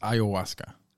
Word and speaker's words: ayahuasca: It's pronounced ayahuasca.